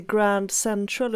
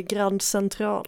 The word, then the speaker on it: Grand Central: Sen har ju Jernhusen gått ut nu, om vi ska göra lite uppföljning, och sagt att det är inte Grand Central utan det är Grand Central.